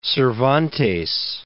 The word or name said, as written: Cervantes